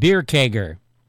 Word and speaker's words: Baerkircher: But it wasn't spelled that way. It was spelled B-A-E-R-K-I-R-C-H-E-R, like Bearkircher. But it was always pronounced Bierkegger.